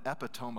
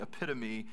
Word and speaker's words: epitome: But I also realized that some of you, English is not your first language, and so you actually, the right way to say that is the epitome of hyperbole, but the way that you spell it, we're sorry for our language.